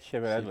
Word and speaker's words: Shyamalan: Et, je crois qu'un autre bon exemple de fusil de Tchekhov, c'est dans le film Signe de euh, Monsieur Knight Shyamalan. Je crois que je l'ai bien prononcé. – M. Night Shyamalan, ouais, tout à fait.